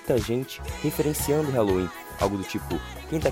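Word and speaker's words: Helloween: Na verdade, a diferença é que no lugar da letra A, temos um E, mas a pronúncia é a mesma, Halloween.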